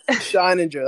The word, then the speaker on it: Scheininger: Founder and CEO of Tropical Seas, Tyler Blendowski, and the co-founder, Jared Scheininger. Did I say that right, Jared? Man, I butchered that. – Scheininger. That's all right, brother. I got Scheininger.